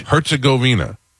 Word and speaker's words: Herzegovina: Herzegovina.